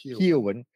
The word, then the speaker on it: Keoghan: Keoghan.